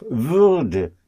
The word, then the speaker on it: Würde: So you see now why I insisted on the pronunciation of, of Würde, yes? – yes? – To make that sound distinction between Würde for would and Wurde for was getting, was becoming, was getting.